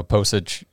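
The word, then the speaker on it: Pocic: Posich, that's how you say his name? – Yep.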